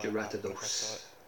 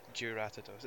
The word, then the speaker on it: Jyuratodus: Jyuratodus. – Jyuratodus.